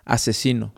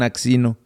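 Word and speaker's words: Aczino: Pero lo, se escribe A, e, C, Z. Entonces hay gente que no sabe cómo se pronuncia y le dicen axino. – Pero es asesino.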